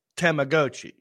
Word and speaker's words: Tamagotchi: You meant to say Tamagotchi? – Pronounced Tamagotchi, yes.